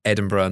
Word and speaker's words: Edinburgh: There's sad parts in it, but it shows off Edinburgh, and that's Edinburgh, not Edinburgh or Edinburgh, as Americans say it.